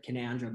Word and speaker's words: Canowindra: It's said Canowindra, but it's Canowindra. There you go. I told you I didn't want to say that, right? So I've said it the way it's spelt.